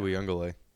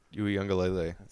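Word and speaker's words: Uiagalelei: Yeah, that last name's Uyungalele, I think is how it's said. – Yeah. Uyungalele. Uyungalele.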